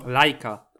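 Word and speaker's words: Leica: P20 ma podwójny aparat lejka. Klasiko. – Lajka. To się tak wymawia, purysto.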